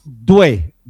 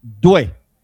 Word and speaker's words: Doué: Sí, sé que se escribe Doué y muchos narradores colombianos le dicen así, pero es Doué, Doué.